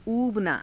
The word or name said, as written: uubanaa